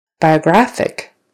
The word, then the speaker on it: biographic: Biographic. Biographic. Now it's on the third syllable.